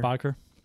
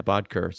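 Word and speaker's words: Bødker: Do you pronounce it Bodker? – Bodker, yeah, Bodker.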